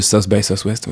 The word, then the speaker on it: South by Southwest: Le South by Southwest, oui.